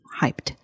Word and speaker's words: hyped: OK，那大家有注意到我们刚刚把唯一念的还蛮清楚的，因为它是一个过去式，所以接在 P 后面的那个 ED 我们是念成 T T 的音 Hyped。